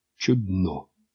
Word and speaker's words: чудно: Давайте-ка для примера поставим два разных ударения на коротеньком слове «чудно». На первый слог значит «прекрасно», «замечательно». На второй «удивительно».